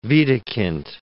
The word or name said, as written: Widukind